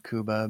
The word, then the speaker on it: Cuba: However, I will say that uh, he pronounces his name Cuba just like the country.